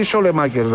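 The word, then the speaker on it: Saelemaekers: Αυτός λέγεται Σαλεμάκερς ή Σολεμάκερς, ρε παιδιά. – Σα, είναι ΑΕ. – Άμα είναι ΑΕ, είναι όμικρον. Διαβάζεται όμικρον.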